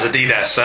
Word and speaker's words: Adidas: And on that bombshell, I'm afraid points knocked off to Gary there for pronouncing Adidas as Adidas.